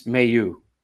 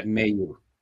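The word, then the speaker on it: Mailloux: It's Mayu, Logan. Okay. Mayu. Yeah, that's how he grew up. You know, pronounced his name was pronounced, and that's how everybody pronounced it growing up. For him growing up, that's how the family pronounces it. So, you know, yeah. I mean, it's a French name with an Ontario pronunciation.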